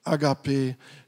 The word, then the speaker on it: Agape: Und dass die einzig wahre Liebe, die Liebe Gottes ist, im Griechischen Agape, so spreche ich das Wort aus. Andere sagen Agape, ja. Okay, ich weiß nicht, wie es richtig ausgesprochen wird.